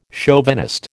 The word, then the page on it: chauvinist: American English